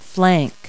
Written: flank

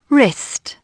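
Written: wrist

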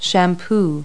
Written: shampoo

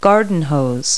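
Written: garden hose

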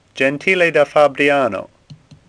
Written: Gentile

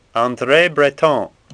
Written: Breton

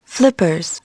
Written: flippers

